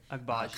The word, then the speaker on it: Agbaji: The Cavs sent Lauri Markkanen, Ochai Agbaji, I think I pronounced that right. – Agbaji.